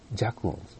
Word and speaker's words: 弱音: こういう感じの音を出すジャクオンズ。森本愛さんがね、またええこと言ってください。弱音と書いてジャクオンって言ってましたからね。僕このフレーズすっごい好きやな。弱音と書いてジャクオン。